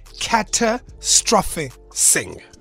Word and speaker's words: catastrophising: It is called catastrophising. So like a catastrophe, but it's in your mind.